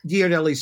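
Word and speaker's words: Ghirardelli: Giardelli Square? – Yeah.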